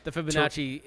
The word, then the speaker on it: Fibonacci: Okay, I am going to avoid the fettuccine sequence. – Fibonacci. It's the Fibonacci sequence. Say it right.